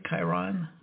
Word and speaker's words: Chiron: But before we dive into it, I think we should get some vocabulary clear for the audience, because the Chiron effect, is that pronounced right, Chiron? – Yes.